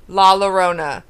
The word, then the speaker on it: La Llorona: We have Candyman, Evil Dead, La Llorona. Am I saying that right? – Yeah.